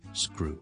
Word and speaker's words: screw: 好啦，前面加个 s 音，而嗰个 crew 咧，因为前面有个 s 音咧，我就通常就唔使喷气。读成好似 grew 咁就得噶啦，grew grew grew，加个前面 s 音，screw screw screw screw，螺丝批我哋叫做 screwdriver，仲有啲咩系有啲咁嘅 s k o 音咧？